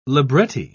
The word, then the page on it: libretti: British English